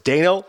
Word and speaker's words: Dano: And Paul Dano, who's often, by the way, his name is pronounced Paul Dano. People always screwed up. I read this article. He said it's Dano like Drano.